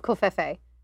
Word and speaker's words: Covfefe: Another Jonathan says, "Dear Master of Covfefe." Now, do I say that right? – You're saying it right.